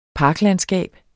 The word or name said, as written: parklandskab